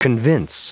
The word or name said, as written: convince